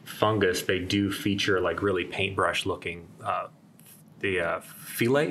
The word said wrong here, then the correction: hyphae: The hyphae.